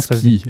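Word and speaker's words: squille: Maintenant, on va en parler concrètement de cet animal, de cette menthe marine, qu'on appelle aussi skill. Je ne sais pas comment ça se prononce. Comment ski. – Ça se dit. Ski, voilà. – Comme la descente, comme la descente à ski.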